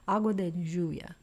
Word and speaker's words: Agua de Lluvia: Well, I don't think we should wait any longer to hear this wonderful piece, Rainwater, Agua de Lluvia. – Yes. – Very good. Did I say it right? – Yep.